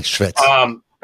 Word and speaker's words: Shvets: Is that, am I saying it right? – How do you say? So. It? Yes, I think you're absolutely right, Shvets.